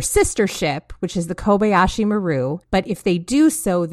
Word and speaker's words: Kobayashi Maru: So can you run us through the, please tell me if I'm pronouncing this wrong as well, but the Kobayashi Maru. Did I say that correctly? – Perfect.